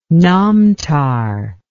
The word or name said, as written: Namtar